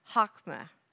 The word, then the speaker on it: chokma: It's spelled C-H-O-K-M-A, but pronounced chokmah.